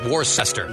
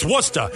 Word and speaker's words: Worcester: I'm sorry, I mispronounced that. Worcester. – Worcester. – All right. – It's Worcester, kid.